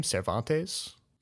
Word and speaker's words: Cervantes: Cervantes? – Yes.